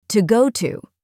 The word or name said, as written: to go to